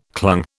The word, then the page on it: clung: British English